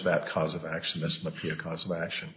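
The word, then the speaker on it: MHPAEA: It's a good question as to how that acronym is pronounced, but the folks at the Department of Labor pronounce it MAPEA.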